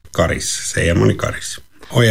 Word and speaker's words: Karis: Hur man riktigt ska uttala namnet på, ja, den där orten som har en viktig järnvägsstation, handbollslaget BK46 och som numera hör till Raseborg. Karis, eller Karis.